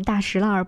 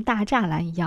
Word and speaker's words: 大栅栏: 就像大石烂不能读成大栅栏一样，如果你把沙滩儿读成了沙滩的话，可能很多人都不知道你在说的是什么地方。